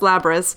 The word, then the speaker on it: Labrys: All signs point to it being pronounced Labrys.